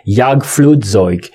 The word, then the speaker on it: Jagdflugzeug: Yeah, the first letter in NIMSA, which is, the second letter in NIMSA, which is pronounced in German as a Y sound, but is written with a J, Jagdflugzeug. Jagdflugzeug, it means hunting, flying, literally.